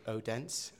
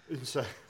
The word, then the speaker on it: Odense: We English people pronounce it Odense. – Well, just when we're looking at the football scores, but we've learned that it's Unser.